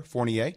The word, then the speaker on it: Fournier: That's how you pronounce his name for everybody out there, Fournier.